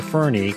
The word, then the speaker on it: Fernie: Alrighty, back at you here, Greg Kokel, Stand to Reason, and we have got, now I want to say Fernie, because that's what it says here.